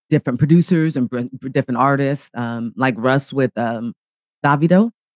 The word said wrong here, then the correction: Davido: I know he always says I say it wrong all the time. – Little bro. – Yeah. Our little bro who's Nigerian, he likes to correct me. And I'm like, well, I don't speak the tongue of Africa or Nigeria. I'm saying it the way that I read it, but he's another good producer and he's huge. He's huge. And what is his name? – Davido. Oh my God, let me spell it right. D-A-V-I-D-O.